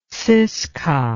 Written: ziska

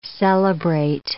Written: celebrate

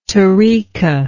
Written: tariqah

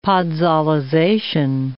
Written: podzolization

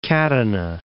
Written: Catana

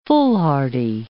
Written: foolhardy

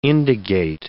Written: indagate

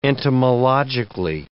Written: entomologically

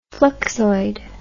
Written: fluxoid

